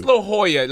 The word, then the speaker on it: La Jolla: I'm like, what the fuck It's La Jolla.